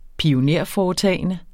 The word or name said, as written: pionerforetagende